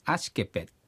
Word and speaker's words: アシケペ: 指はね、アシケペ。 – アシケペ。 – うん、アシケペ。 PET みたいなね、発音になります。